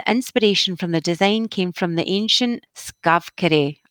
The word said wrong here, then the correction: Stavkirke: That's not how you say it. – Stavkirke. – Stavkirke, thank you.